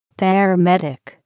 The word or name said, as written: paramedic